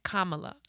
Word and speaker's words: Kamala: Kamala.